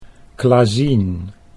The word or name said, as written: Klazien